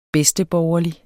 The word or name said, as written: bedsteborgerlig